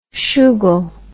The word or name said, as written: shoogle